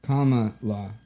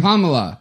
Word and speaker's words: Kamala: The verse is Kamala. – Oh, okay. I'm not going to. – Kamala. – Kamala. That's right. Kamala. Duh. Sorry. I was just really trying to read it phonetically today. It's Kamala.